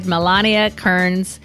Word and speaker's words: Milana Kearns: Milana Kearns.